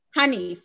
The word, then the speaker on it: Haneef: How do you say his name? – Who? – Hanif. – Hanif. Yes, Hanif.